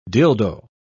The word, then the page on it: dildoe: British English